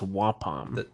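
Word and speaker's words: Ua'pam: So the way that they pronounce the spren's name in the audiobook is Wapom. – Okay. – Right. I've always heard it as Wapom.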